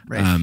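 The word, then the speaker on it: Raiff: This is written directed by Cooper Rafe. I'm assuming it's pronounced Rafe or Raif. I don't know. – Rafe, yeah, I think so. – Rafe, thank you.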